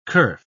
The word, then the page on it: kerf: British English